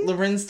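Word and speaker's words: Larenz: Do I say his name right? – Lorenz Tate. – I feel like Lorenzo. I don't know. Is it? – Don't do that. – Okay. – Lorenz.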